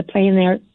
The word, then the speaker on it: plein air: Now, I know you're also doing something called, is it the plein air painting? Am I saying that right? – Yeah, that's what this is the plein air paint out.